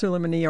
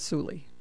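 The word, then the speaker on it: Sulaymaniyah: It's pronounced Suleimani or Suli. The people who live there call it Suli.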